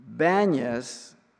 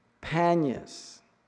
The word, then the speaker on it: Banyas: The Arabs cannot pronounce the letter P. They pronounce it as B. And so they call Banyas, Panyas. They can't pronounce it. Panyas, the god Pan.